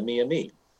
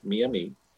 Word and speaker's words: Miami: There's just three different pronunciations of the same tribe. One is what they call themselves, Miamis. One is the mispronunciation in English, Miami, and the other is the mispronunciation in French, which is Maumee.